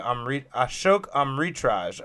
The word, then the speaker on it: Ashok Amritraj: Ashok Amritraj.